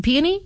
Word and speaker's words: peony: Is that the correct pronunciation? – Peony? Yeah. – Peony? – It's three syllables. – Yes. – Peony.